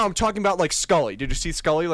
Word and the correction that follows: Sully: Sully. – Oh, sorry, I said it wrong.